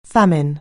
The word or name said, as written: famine